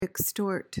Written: extort

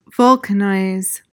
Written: vulcanize

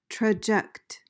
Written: traject

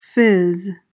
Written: phiz

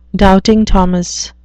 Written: doubting thomas

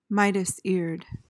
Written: midas-eared

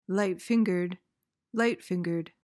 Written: light-fingered

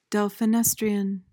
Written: delphinestrian

